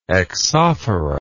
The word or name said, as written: exophora